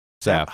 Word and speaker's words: salve: And there's some sort of a salve that you can, not salve, salve? – Salve. – How do you pronounce it? – I think it's salve.